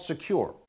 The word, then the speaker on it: Sekur: It's called Secure. It's spelled S-E-K-U-R.